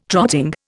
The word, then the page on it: trotting: Indian